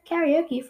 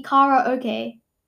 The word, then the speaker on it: karaoke: And he's like, dude, how the heck did they get karaoke from... Okay, anyways, to get to the point, it says... He said that it actually rhymes with Lala okay. So it would be Kara okay. Okay? It's not karaoke. How'd you get that from K-A-R-A-O-K-E?